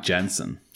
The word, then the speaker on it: Janssen: It's actually Mike Janssen. – Mike Janssen. – Yeah, he told us on Discord it's a hard J.